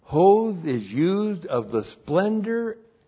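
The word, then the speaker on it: hoth (הוד): And then 3, the branch, the Messiah, will bear or be clothed with hoth. H, long O-D, hoth.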